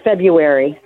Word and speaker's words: February: No, it's February. – Thank you. – February. And we don't pronounce the R. It sounds ridiculous.